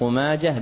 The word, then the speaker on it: ماجه: يقول: أورد ابن ماجه وكتبها بالتاء، وسبق أن ذكرنا لكم بيتا وهو يا صاحي نطق ماجه بالهاء بلا لجاجة، يعني كلمة ماجه تنطق بالهاء لا تاء فيها.